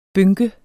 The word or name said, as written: bynke